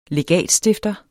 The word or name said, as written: legatstifter